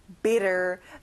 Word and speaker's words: bitter: 여러분, T가 있어도 T 발음은 안 나요. Bitter.